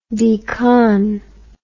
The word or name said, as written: decon